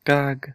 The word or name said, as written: gag